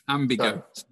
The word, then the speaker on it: Ambigo: I would like to know more about Imbigo. How do you say it? Imbigo? – Ambigo. Ambigo. Go, because we help ambitions go places.